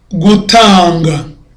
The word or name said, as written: gutanga